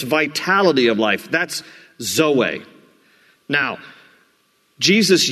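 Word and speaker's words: Zoe (ζωή): Z-O-E. Usually, for a girl's name, it's pronounced Zoe, but in the Greek, the accent is on the last part, Zoe, and it means life, and it means a fulfilled life.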